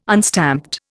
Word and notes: unstamped: Indian

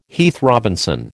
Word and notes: Heath Robinson: American English

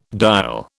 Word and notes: dial: British English

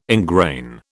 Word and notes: ingrain: British English

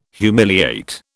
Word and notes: humiliate: British English

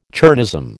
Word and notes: chernozem: American English

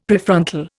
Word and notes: Prefrontal: Indian

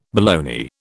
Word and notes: baloney: British English

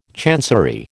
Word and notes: chancery: American English

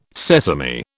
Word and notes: sesame: British English

